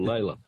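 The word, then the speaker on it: Lalor: Or Layla. Lawler is how it's supposed to be pronounced, but Layla's the other way.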